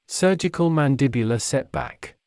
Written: surgical mandibular setback

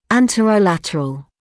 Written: anterolateral

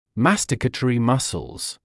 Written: masticatory muscles

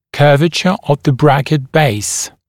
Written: curvature of the bracket base

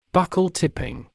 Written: buccal tipping

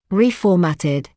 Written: reformatted

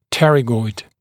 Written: pterygoid

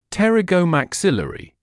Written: pterygomaxillary

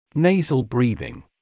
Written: nasal breathing

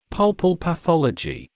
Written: pulpal pathology

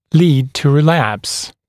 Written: lead to relapse

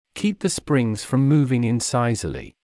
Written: keep the springs from moving incisally

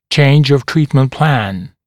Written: change of treatment plan

